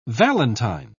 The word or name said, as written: valentine